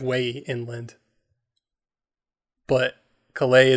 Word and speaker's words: Calais: Calais? How do you say that? – Calais? – Calais.